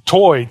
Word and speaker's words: Toi: You know, you might be tempted just, well, how do you pronounce these? Toy, T-O-I. Like Toy Story, like King of Toy.